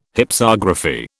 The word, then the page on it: Hypsography: British English